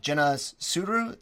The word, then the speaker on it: Jenna Suru: Jenna Suru, am I saying that right? – Yes, exactly.